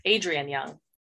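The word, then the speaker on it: Adrienne: We're talking about Namesake by Adriana Young. Is that how you say her name? Adrian Young. Namesake Adrian. By Adrian Young.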